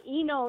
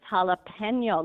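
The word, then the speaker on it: jalapeño: I don't hang on the N. Yeah. – It's not Eno either. Mm-hmm. It's jalapeno. Look how it's spelled. Spanish is always said exactly the way it's spelled every time.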